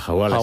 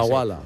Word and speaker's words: Hawala: Y el Wahala, creo que se dice así. – Sí, la Hawala. – Hawala.